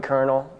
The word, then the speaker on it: colonel: William put colonel, and I think... – Yep, colonial. I think it's spelled colonial, right? – Yeah, it's spelled colonial, but it's actually pronounced colonel.